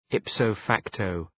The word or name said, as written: ipso facto